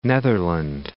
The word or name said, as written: Netherland